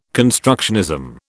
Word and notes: constructionism: British English

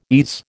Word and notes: ease: American English

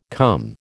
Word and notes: cum: American English

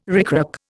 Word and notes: ricrac: Indian

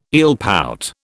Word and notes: eelpout: British English

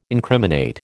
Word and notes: incriminate: American English

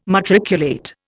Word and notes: matriculate: Indian

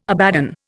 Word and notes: Abaddon: Indian

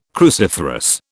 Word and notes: cruciferous: British English

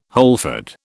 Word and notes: wholefood: British English

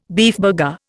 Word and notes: beefburger: Indian

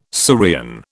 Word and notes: Syrian: British English

